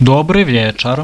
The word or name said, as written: dobry vieczar